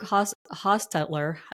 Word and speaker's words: Hostetler: I have a special guest today, my friend Luke Hostetler. Do I pronounce that properly? – Yeah, if you're pronouncing it the German way, yeah.